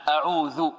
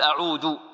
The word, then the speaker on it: أعوذ: نريد أن نركز على حرف آخر وهو الذال هنا، أعوذ، لأن هناك من ينطق بالذال دالا يقول أعود، ولا شك أن أعوذ غير أعود، يعني أعوذ يعني ماذا؟